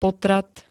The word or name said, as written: potrat